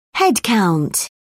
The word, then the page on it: headcount: British English